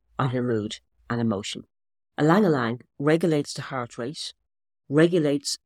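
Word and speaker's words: ylang-ylang: Ylang ylang is actually pronounced E but spelled Y. Ylang ylang,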